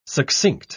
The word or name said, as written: succinct